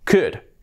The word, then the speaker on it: could: Otro modal, cuidado la pronunciación, que no es cold, no. Could, could. La L fuera, could.